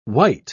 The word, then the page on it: wight: British English